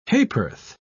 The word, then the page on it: ha'p'orth: British English